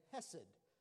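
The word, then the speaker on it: hesed (חסד): But it's hen, and it looks like C-H-E-N, but it's pronounced hen. And then hesed, and it's also wrote C-H-E-S-E-D.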